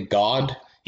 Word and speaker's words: Gawd: The intended pronunciation is most definitely God, yes. – But it's spelled G-A-W-D.